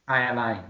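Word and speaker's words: jai alai: Jayalai or Khayalai? – Khayalai, yeah. Khayalai means happy fiesta.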